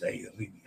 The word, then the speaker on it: Arabia: Arabia. Roll the R. You're the only cruise lager that cares enough to properly pronounce Arabia. Yes, I try. Arabia.